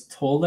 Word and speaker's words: Tolle: I've always said Eckhart Tolle, and then you told me it was Tolle, and then I'm like, Tolle, okay. So now that's stuck in my head since, like, what, 4 years ago?